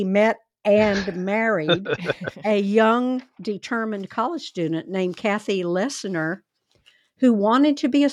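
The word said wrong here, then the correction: Leissner: I think that's pronounced Leisner.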